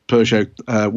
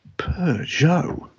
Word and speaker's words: Peugeot: And at the end of it, this very feminine, sexy French female voice would pronounce it in that way that only a French lady can. Peugeot. Peugeot. Peugeot. It's Peugeot, not Peugeot.